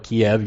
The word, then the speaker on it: Kyiv: Kyiv, if you spell it K-I-E-V, is a transliteration from the Russian. And so it's not wrong to call it Kyiv, you're just following the Russian spelling. If you do it K-Y-I-V, Kyiv, then this is from the Ukrainian.